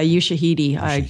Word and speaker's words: Ushahidi: Yeah, Ushahidi.